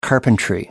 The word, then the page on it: carpentry: American English